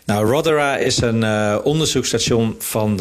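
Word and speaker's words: Rothera: Ja, want jij beschrijft onder andere het onderzoekstation uh, eh, Rotera, zeg ik dat goed? – Ja, nou, het is een Britse station, Rotera. Rotera, ja.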